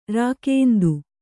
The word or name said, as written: ರಾಕೇಂದು